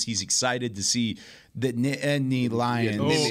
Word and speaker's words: Nittany: Yeah, you gotta throw the T's in. – Yeah, a nitany. – A lot of people just roll through it, and it's almost like 2 syllables, but it's Nittany Lions.